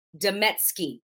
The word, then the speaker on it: Demetsky: And today I am with Mandy Demetsky.